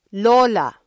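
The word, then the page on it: lola: Filipino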